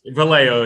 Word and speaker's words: Vallejo: The number five, no, Alex Vallejo. – Vallejo, yes, yeah. We've all got used to our Spanish pronunciations.